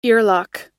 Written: earlock

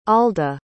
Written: alda

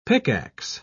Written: pickax

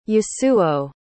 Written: Yasuo

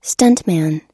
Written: stuntman

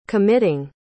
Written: committing

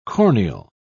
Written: corneal

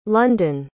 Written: lunden

